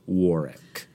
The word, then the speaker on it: Warwick: I had the pleasure of visiting Warwick Castle on my tour of the UK and Ireland when I was 15, and it's definitely pronounced Warwick.